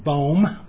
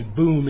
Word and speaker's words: Boom: Now, I think we know the name, as rightly pronounced, as Corrie ten Boom. We tend to say boom in English, but it's really Boom in Dutch.